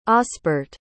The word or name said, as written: osbert